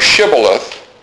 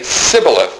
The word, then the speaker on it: Shibboleth: Well, an Ephraimite would come across, and they would say Shibboleth, and they would say Sibboleth. They would leave the S-H out of it.